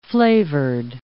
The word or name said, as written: flavored